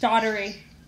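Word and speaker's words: Dougherty: And so she had the choice of marrying their neighbour, who was James Daugherty. I think that's how it's pronounced. – Daugherty. – Yes.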